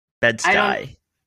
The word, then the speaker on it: Bed-Stuy: Bed-Stuy.